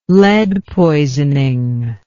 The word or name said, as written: lead poisoning